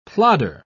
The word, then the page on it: plodder: British English